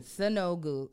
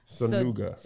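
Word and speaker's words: Sonuga: P-E-P-I. – P-E-P-I. – Okay. Last name, S-O-N. – Sanogu. I'm sorry. – Sanuga.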